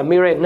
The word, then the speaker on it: Mirren: Mirren đọc là Mirren nữa